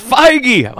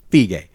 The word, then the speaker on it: Feige: It's Feige. Why can't you guys say it right? It's so simple. – I have it written right here. Feige.